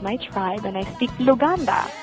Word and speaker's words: Luganda: The language that we are talking about is Luganda? – Correct. You said it right. That's Luganda without an N on the end. Most people say Luganda, and I'm like, no, there's no such thing. It's Luganda.